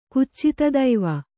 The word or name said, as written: ಕುಚ್ಚಿತದೈವ